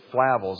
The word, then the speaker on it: Flavel: John Flavel, I'm going to pronounce his name, it may be Flavel, but it's Flavel is the way I say it.